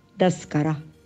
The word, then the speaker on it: تذكرة: وفي نهاية هذا الحرف نجد أن كلمة تذكرة أي ورقة السفر فهم ينطقونها دسكرة بالدال.